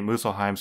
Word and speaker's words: Muspelheim: There's a P in there that I'm not pronouncing, but I think it's pronounced Muspelheim. It's Muspelheim.